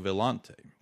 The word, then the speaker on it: Villante: And uh, wrapping up the show, we're going to have BRL Equine's Joe Villante, Joe Villante. I didn't want to give the Spanish pronunciation; I wanted to give the Italian pronunciation.